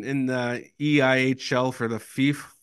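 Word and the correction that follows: Fife: Eh, did I say it right? FIF? – No, it's five, but that's close enough.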